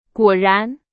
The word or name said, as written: 果然